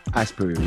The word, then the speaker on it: Aspiro: Um, he then launched um, a company called Aspiro. Am I pronouncing it right? Asperu, yeah.